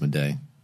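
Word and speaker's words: Maday: How would you say this? It's M-A-D-A-Y. – Yeah, it's Mede.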